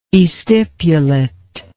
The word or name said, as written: estipulate